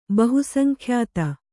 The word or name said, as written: ಬಹುಸಂಖ್ಯಾತ